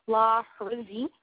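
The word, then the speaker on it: Lahrizi: Sorry, it's L-A-H-R-I-Z-I. I'm sorry. Forgive me for pronouncing it funny. I'm trying to pronounce it the best I can. Last name, La Rizzi.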